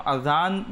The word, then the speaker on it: আজান: তো খেয়াল রাখতে হবে আমরা যেন আজান ব্যবহার করি, তো এটা সঠিক উচ্চারণ এটি।